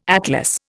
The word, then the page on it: atlas: Indian